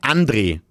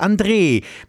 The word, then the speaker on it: André: Ganz viele Menschen sagen zu mir André. Und ich sage dann immer, du sagst ja auch nicht Andreas. Ich heiße André mit dem langen E, so wie Andreas.